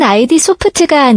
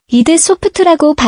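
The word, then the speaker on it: id Software: 원래는 ID 소프트가 아니라 이드 소프트라고 발음하는 것이 맞아요.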